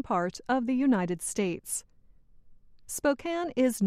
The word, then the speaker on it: Spokane: The correct pronunciation is Spokane, not Spokane.